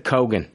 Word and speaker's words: Keoghan: How to pronounce Barry Keoghan, Kogan, and it said Kogan.